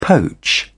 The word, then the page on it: poach: British English